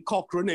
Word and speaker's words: Cochran: Now, Tony Cochran, emphasis on the first syllable,